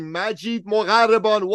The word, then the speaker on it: Majeed Mogharreban: I am speaking, of course, of none other than the one, the only, the legendary Majeed Mogharreban.